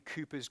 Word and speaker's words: Cowper: I opened my sermon last Sunday morning, didn't I, by quoting a few verses from William Cowper's great hymn, "God Moves in a Mysterious Way." And it is Cowper, by the way, not Cowper.